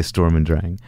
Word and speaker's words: Sturm und Drang: We're in the United States, so I would say Sturm und Drang. – Sturm und Drang? – Yeah.